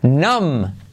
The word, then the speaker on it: numb: Numb. Numb. Esta palabra quiere decir como endormecido, como cuando se te duerme el brazo, la pierna, ¿vale? Numb. Numb, ¿ok? So, no pronuncias la B, numb.